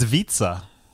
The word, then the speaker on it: Vitsa: It rhymes with pizza. It's Vitsa.